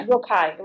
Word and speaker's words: Rocaille: Yes, the 1946 painting, which I think is the Rocaille chair. I'm not sure I'm saying that right. – Yeah, Rocaille, right.